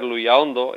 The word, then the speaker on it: Luyaondo: Cuando hay cuatro sílabas, es que es automático, además que va en la segunda sílaba, la fuerza más importante de todo. El pueblo de Luyaondo, ahora es Luyaondo, ya lo hemos cambiado también. Cuando iba sobre la A, por eso en castellano, cuando se ha simplificado, se ha mantenido la A, no la O, ¿no?